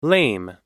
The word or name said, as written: lame